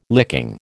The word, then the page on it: licking: American English